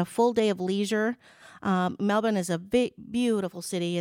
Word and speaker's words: Melbourne: Looks like Melbourne, but they pronounce it Melbourne, Australia.